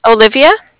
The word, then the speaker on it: Olivia: Olivia.